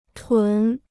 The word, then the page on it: 屯: tún